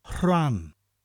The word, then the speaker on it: Rhan: Rhan is spelt R-H-A-N. You're basically saying the English word ran, but with a slight h sound in front of it. Rhan. And they roll together the H and the R.